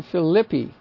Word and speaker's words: Philippi: So the accent being on the consonant, the vowel A is still short, like Lystra, Neapolis. And from there to Philippi would be the correct pronunciation of that.